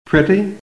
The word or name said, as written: PRETTY